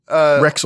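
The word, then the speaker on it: Rexall: Her family owned a Roxel pharmacy. Did I say that right? – Rexel. – Like Rexel.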